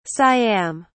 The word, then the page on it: siam: British English